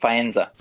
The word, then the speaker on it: Faenza: And that Faenza, how do you say it? Faenza? What? – Faenza.